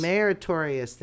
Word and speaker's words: Meritorious: the Defense Meritorious Service Medal? Meritorious? Did I say that right? – Probably. – Okay. While deployed in Afghanistan... – No, Meritorious. – Meritorious, thank you, Service Medal.